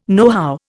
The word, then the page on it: nohow: Indian